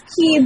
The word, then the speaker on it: Kii: Is it the Kii Peninsula or Kai? How do you pronounce? – It's Kii, but it's in Wakayama Prefecture around the coast.